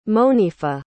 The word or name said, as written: monifa